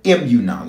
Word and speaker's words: emunah: One of those Hebrew words for faith is the word emunah. Let me spell it for you, and then I'm going to have you type it. E-M-U-N-A-H, emunah.